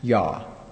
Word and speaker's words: Jah: took the spelling of I-A-H, or J-A-H, or I-A, but it was always pronounced Yah.